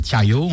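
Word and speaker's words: chả giò: c'est les tiayo, on dit, c'est ça.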